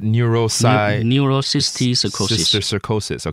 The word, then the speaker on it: Neurocysticercosis: Neurocysticercosis.